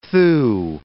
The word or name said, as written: thew